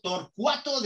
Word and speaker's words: Torcuato: Eh, Augusto, licenciado en Economía eh, por la Universidad de Tortuaco, Torcuato de eh, Ditea, creo que lo he dicho bien, italiano, ¿verdad?